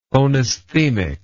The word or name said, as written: phonesthemic